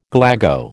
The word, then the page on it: galago: American English